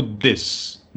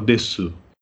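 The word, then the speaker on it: des (です): Eu tinha um professor lá no Japão, no do, do curso de línguas, que ele falava desu. Ele fazia o U, ele, falava, ele pronunciava o U, apesar de que todos os outros professores falavam des, né? Mas com a, a pronúncia do U oculta, né?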